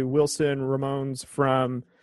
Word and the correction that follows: Ramos: Uh, Wilson Ramones, I think that's how you pronounce his name. Wilson Ramos. – Ramos. Um, he's one that just signed with the Mets this offseason.